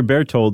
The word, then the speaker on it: Berthold: Berthold? – Yeah. – Okay. His brother Berthold, his older brother, was in this plot too.